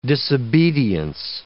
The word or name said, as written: disobedience